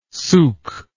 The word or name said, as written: suq